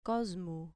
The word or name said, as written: cosmu